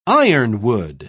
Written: ironwood

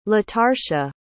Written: latarsha

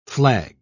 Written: flag

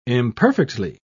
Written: imperfectly

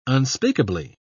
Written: unspeakably

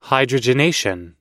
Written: hydrogenation

